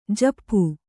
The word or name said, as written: ಝಾಪು